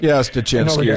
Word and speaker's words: Stachinski: How do you say his last name? – Stachinski is what we've been going with.